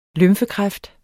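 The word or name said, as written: lymfekræft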